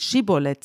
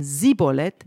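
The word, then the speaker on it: Schibboleth: Und wenn derjenige, der darüber wollte, dann nicht Schibolet sagte, sondern Sibolet, dann haben sie gemerkt, er kann es nicht richtig aussprechen und ergriffen ihn und machten ihn dort noch an den Furten des Jordan nieder.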